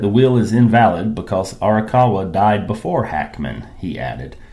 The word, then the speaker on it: Arakawa: Arakawa.